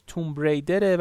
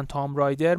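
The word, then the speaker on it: Tomb Raider: تلفظ دقیقش توم ریدره ولی خب چون از قدیم تو ایران میگفتن تام رایدر منم از الان به بعد میگم تام رایدر پس حواستون باشه که درستش توم ریدره نه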